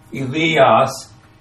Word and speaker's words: Elias: Long A, Elias, and that, not surprisingly, is Elijah.